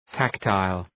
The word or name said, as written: tactile